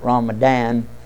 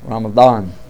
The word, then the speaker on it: Ramadan: And I want to pronounce it correctly. Ban, not Ramadan. Ramadan. Yeah, that's the letter that's tough on the folks.